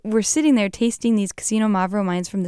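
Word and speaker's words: Xinomavro: And while I was there, I made a magical discovery with Casino Mavro. This is a very hard to say grape variety. It starts with an X, but you say it like Casino Mavro.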